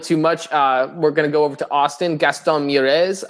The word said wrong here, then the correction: Mieres: led the way for Toronto. Is he even French? Why am I pronouncing him like that? – Mieres. – Yeah. – There we go.